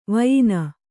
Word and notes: ವಾಯನ: vāyana

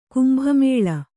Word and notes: ಕುಂಭಮೇಳ: Kannada

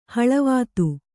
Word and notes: ಹಳವಾತು: haḷavātu

